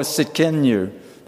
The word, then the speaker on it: Tsidkenu: In the Hebrew, it's called Jehovah Tsidkenu. that we just sang about. The T is silent.